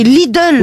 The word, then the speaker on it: Lidl: Il n'y a pas de O, c'est Lidl. – Oui, Lidl.